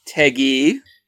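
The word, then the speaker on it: Tagge: Jerry Teig, is that how you say it? – Teggy.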